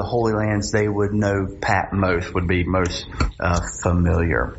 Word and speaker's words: Patmos: Now, some people say Patmos. That's uh, uh, but the original language is a long O, so it would be Patmos.